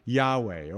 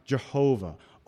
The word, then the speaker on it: Yahweh: Only the high priest could pronounce the name, the sacred tetragrammaton, YHWH, which we pronounce Yahweh, or we pronounce Jehovah.